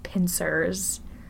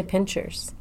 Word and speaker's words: pincers: It opens, and three creatures walk out, and they have a robot slit mouth, crab-like pincers. They're about 5 feet tall. – You say pincers? – Pincers, like, beep, beep, beep. – I say pinchers. – But this is, it's spelled P-I-N-C-E-R-S.